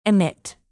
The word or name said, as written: emit